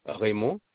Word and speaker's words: Raymond: Raymond.